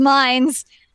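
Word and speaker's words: Mainz: shot in mains, or as I like to call it in this game, mines. – I'm so mad that it's pronounced mines.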